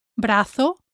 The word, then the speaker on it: brazo: Avoid adding a U sound at the end of brazo, cuello, cuerpo, dedo, mano.